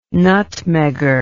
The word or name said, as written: nutmegger